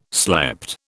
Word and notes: slept: British English

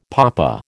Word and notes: papa: American English

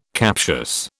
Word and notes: captious: British English

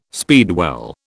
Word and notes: speedwell: British English

